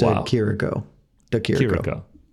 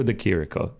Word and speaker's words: De Chirico: I believe it is just De Chirico. – Yeah. Alessio De Chirico. There it is. Saying it properly.